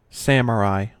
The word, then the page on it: samurai: American English